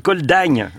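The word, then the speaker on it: col d'Agnès: Le col d'Agne qui s'écrit d'ailleurs Agnès.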